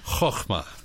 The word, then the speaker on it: Chokmah: The first time we see this word wisdom, by the way, you really have to have an allergy problem to pronounce the Hebrew word for wisdom. – What is it? – Chokmah.